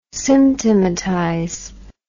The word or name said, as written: symptomatize